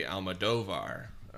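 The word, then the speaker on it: Almodóvar: There's an accent over the O, which makes me think it would be Almodovar. – That's how you say it, the Almodovar.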